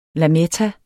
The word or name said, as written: lametta